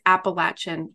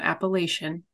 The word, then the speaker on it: Appalachian: All right, so the Appalachian Trail, and it is Appalachian, not Appalachian, for all of those who pronounce it wrong.